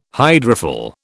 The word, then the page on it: hydrophil: British English